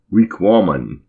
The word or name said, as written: Wiqômun!